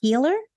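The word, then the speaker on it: Heehler: Sorry, Tom, if I spelled that wrong, but it's if I said that wrong, but it's H-E-E-H-L-E-R, Heeler.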